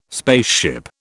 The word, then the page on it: spaceship: British English